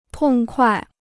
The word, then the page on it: 痛快: tòng kuài